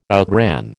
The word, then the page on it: outran: American English